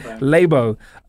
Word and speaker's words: Labo: And now they've come up with this thing called, I'm going to check if I'm saying it right. Is it Labo? – I said Labo.